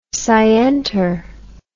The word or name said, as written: scienter